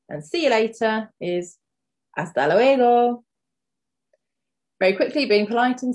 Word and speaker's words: hasta luego: So again, the H in hasta is silent. So hasta luego is see you later.